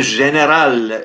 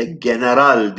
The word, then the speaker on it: general: Türkçe'de neden general değil de general diye telaffuz ediyoruz söz konusu askeri rütbeyi? Sene 1934 müdür acaba? Ee, şimdi eski İstanbul zümreleri general diye söylerdi bu kelimeyi. Çünkü Fransızcası generaldir.